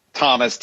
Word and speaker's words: Thomas: Zeke, by the way, is spelled Z-E-K-E. Thomas, T-H-O, M like Mary, A like Apple, S like Sam.